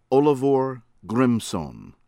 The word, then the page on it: ARNASON, THOROLFUR: TOHR- ohl-vehr AHR- nah-suhn